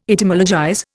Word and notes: Etymologize: Indian

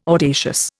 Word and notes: audacious: Indian

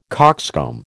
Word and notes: coxcomb: American English

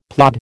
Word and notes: plod: American English

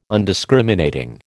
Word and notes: Undiscriminating: American English

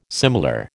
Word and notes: similar: American English

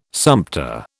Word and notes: sumpter: British English

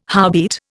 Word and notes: howbeit: Indian

